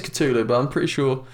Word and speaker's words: Cthulhu: Some sort of Cthulhu yeah. – thing. And I think it's actually pronounced Cthul, but...